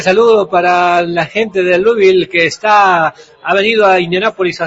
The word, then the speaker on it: Louisville: Para el equipo de Louisville.